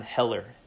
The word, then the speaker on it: Hoeller: It's by one of my favorite modern Gnostics, Bishop Stefan Heller. His last name is spelled H-O-E-L-L-E-R.